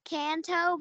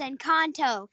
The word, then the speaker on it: Encanto: She's saying can't. Canto, but it's Encanto.